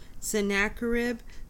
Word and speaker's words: Sennacherib: Sennacherib, whose armies had captured all the fortified cities of Judah, sent a message to Hezekiah to surrender.